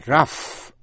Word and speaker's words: rough: Nhưng chữ through, T-H-R-O-U-G-H, through hay là throughout thì G-H câm, O-U đọc là U. Một chữ nữa là rough, R-O-U-G-H, rough. Giáp, nhám, xù xì, thô lỗ, G-H đọc là F.